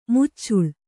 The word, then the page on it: ಮುಚ್ಚಲ: muccala